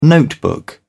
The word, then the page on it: notebook: British English